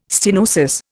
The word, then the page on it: stenosis: Indian